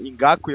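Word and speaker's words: Ngakoue: O draft do Jalen Ramsey, que foi até o que a gente falou ontem lá no programa, que eu falei errado o nome do Ngaku e eu falei em Joko, uhum.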